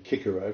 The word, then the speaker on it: Cicero: which should always be Cicero because it's a hard C, and Josephus.